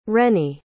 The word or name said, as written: rennie